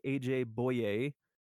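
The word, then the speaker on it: A.J. Bouye: A.J. Boye.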